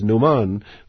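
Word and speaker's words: Noman: Well, I quite like the fact that it's an interlingual pun, because Numan, which is how it would be pronounced in Kashmir, is a perfectly ordinary name, which doesn't mean no man, you know.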